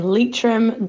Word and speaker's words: Leitrim: I need to correct you on your pronunciations. It's Sligo, Mayo, and Galway. Then Armagh, Tyrone, and Offaly. And then Leitrim, Donegal, and Laois.